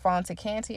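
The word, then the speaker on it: Fanta Kante: And if you would like, you can also follow me on Facebook at Fonta Canty, and it's spelled F-A-N-T-A, last name K-A-N-T-E.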